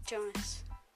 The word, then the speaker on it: Jonas: Bonus rhymes with Jonas, which explains my podcast name.